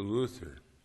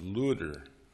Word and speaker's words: Luther: His name in German was Luder, L U T T E R or L U D D E R. Um, he pronounced it L U T H E R, or Luther, punning on the word for freedom in Greek, Eleutherius.